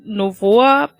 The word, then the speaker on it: Novoa: Ich fand Novoa, wird er so ausgesprochen? – Ja.